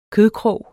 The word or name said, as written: kødkrog